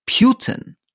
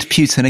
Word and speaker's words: Putin: It's Putin, not Putin.